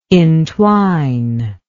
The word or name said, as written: intwine